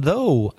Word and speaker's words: though: Be careful of the spelling and the pronunciation. It's very strange. T-H-O-U-G-H. And the pronunciation, though. The same vowel sound as go or throw or no.